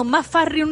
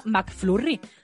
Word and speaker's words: McFlurry: digo, mamá, McFlurry, es que no es tan complicado. Uf, en fin. Bueno, pero, pero la, eh, la U se pronuncia ahí como una A, ¿no?